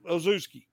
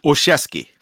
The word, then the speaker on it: Olszewski: Uh, one more thing, o- Oh, Ozuski. How do you say it? – Olszewski.